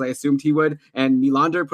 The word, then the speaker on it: Nylander: and then Hyman with Marner and Nylander. And by the way, that's another pronunciation I got corrected on yesterday. It's William Nylander, not Nylander.